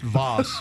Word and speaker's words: Vass: All right, we got Teresa from Vass, North Carolina. – How's it going, John Boy and Billy? – Hey, we're doing good. – It's pronounced Voss.